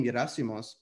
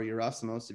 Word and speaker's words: Yerasimos: And when I took ownership of my name, Yerasimos, or Yerasimos, if you're going to pronounce it that way, so much changed in my life, you know? – Yeah.